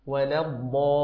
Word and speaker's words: الضالين: كذلك يا أخي الحبيب ولا الضاء ولا الضاء، حاول أن تنطق الضاد مفخمة الألف بعدها بهذا الشكل، ومد الألف لمقدار 6 حركات فتقول ولا الضالين.